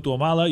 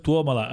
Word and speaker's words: Tuomala: Helsinki és quan Tuomala, que tothom diu Tuomala, i és Tuomala, és esdrúixola també.